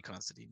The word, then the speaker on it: Considine: Yeah, Paddy Considine.